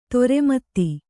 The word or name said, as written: ಧರ್ಮೇತ